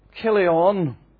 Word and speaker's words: Chilion: Chilion, C-H, is usually pronounced as a K.